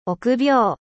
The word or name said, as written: 臆病